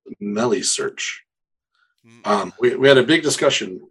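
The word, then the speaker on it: Meilisearch: But no, I think they said Melly, so like rhymes with smelly. – Alrighty, I'll take it. – Melisearch.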